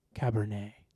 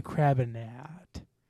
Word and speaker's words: Cabernet: This week we have a story called that I've coined, I Do Not Drink Cabernet. Did I say that right? – Mm-hmm. – Okay. Last night I was just like, Crabinat.